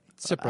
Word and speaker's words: Tsipras: Tsipras. – Alexis. You can't even really say T, can you? Or it's, no, I think it's just Alexis Tsipras.